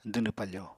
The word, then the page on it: dwnw pafo: apatani